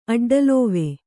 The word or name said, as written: ಅಡ್ಡಲೋವೆ